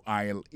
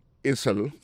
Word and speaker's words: aisle: Aisle là, c'est A-I-S-L-E. Vous prononcez aisle. Il marque Essel.